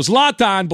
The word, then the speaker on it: Zlatan: Yeah, Zlatan. – Right? Zlatan. All right, so Zlatan believes that LeBron James and other athletes, he was asked about athletes being politically involved and all that.